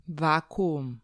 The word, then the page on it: vakuum: Serbian